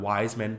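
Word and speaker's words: Weisman: So now people really mispronounce my last name because I'm Wiseman. They're like, oh, but Weissman, because like you know the grocery store chain. I'm like, no, stop it.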